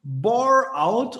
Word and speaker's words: Boreout: Und äh, die heutige, das heutige Thema musste ich erstmal gerade fragen, wie wird das ausgesprochen? Bore out und kommt von boring.